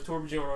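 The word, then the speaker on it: Torbjörn: Tolbjorn. – Tolbjorn. – Tolbjorn. – It's Torbjorn. – What? – Actually, I had somebody insist that it was Torbjorn on Xbox Live.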